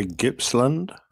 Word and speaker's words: Gippsland: G-I-P-P-S-L-A-N-D G-A-8 Airvan out of Texas. – I would say Gippsland.